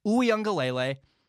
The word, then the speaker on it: Uiagalelei: It's DJ Uiagalelei.